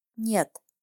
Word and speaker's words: нет: And no is нет. You need to pronounce it as one syllable. It's not нет. It's нет. Нет. Нет. And that's all for now.